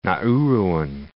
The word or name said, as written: Nauruan